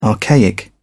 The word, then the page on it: archaic: British English